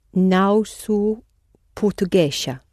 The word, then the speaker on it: portuguesa: Não sou portuguesa. – But the S between two vowels sounds z.